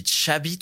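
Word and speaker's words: Xavi: Non, mais déjà, tu dis Chabi, toi. – C'est la prononciation à l'espagnol.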